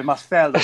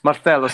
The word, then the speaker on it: Marcelo: Left back, gentlemen, do we have any disagreement about Marcello? Only the, only how you pronounce his name. Sorry, Marcelo. you're Brazilian. Marcelo.